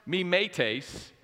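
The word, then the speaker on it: mimētēs: And it's pronounced mimetes, and it literally means to imitate.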